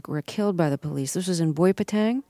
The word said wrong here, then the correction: Boipatong: Am I saying that right? – Boipatong, yes.